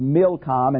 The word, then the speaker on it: Milcom: Now, Milcom seems to be a corruption of the Hebrew root M-L-K, king, Melech. But sometimes in the Bible it's pronounced Milcom and sometimes Molech.